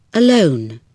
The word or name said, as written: alone